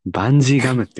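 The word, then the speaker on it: 伸縮自在の愛: 例えばなんか、主要キャラクターのあの、ヒソカっていうキャラクターは、あの、伸縮自前の愛って書いて、バンジーガムって読むんですよ。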